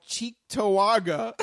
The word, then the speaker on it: Cheektowaga: No, it's a town called Cheektowaga. – Cheektowaga, New York.